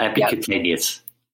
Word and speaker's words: epicutaneous: Epicutaneous.